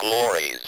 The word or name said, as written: glories